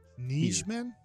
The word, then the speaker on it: Nijman: Starts with a Y. Nijman. Nijman. – Just let it happen, guys. – How would you pronounce N-I-J?